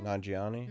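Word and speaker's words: Nanjiani: Nagiani.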